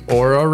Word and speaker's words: Oura: We have the Oura Ring. Am I saying that right? – You are. Oura. – The Oura Ring.